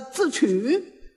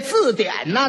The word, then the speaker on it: 字典: 来，说着话，一翻这本书，来，我给你们查查这个字曲。他也错了一个，这字典呢，他给念成了字曲了，合着把底下两点给取消了。